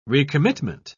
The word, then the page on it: recommitment: British English